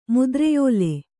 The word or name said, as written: ಮುದ್ರೆಯೋಲೆ